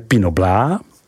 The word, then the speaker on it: Pinot Blanc: Silvánské zelené, Pinot Blanc, vyslovujeme Pinot Blanc, Muscat d'Alsace, vyslovujeme Miska d'Alsace, Pinot Gris, vyslovujeme Pinot Gris, Riesling, tato odrůda je nejvíce v Alsasku rozšířena.